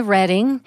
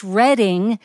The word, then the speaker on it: Reading: You'd think that's the way it's pronounced. No, it's Reading, Pennsylvania, named after Reading, England, the birthplace of William Penn.